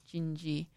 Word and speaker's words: Dindi: That was Astrid Gilberto singing Gingy. It's spelled D-I-N-D-I, Gingy, in Portuguese.